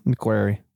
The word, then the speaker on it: McQuarrie: So these are the first two with Christopher McQuarrie, I guess is how you say his last name. – McQuarrie.